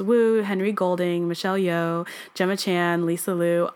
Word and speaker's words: Gemma Chan: Jenna Chan. What is it? Gemma Chan.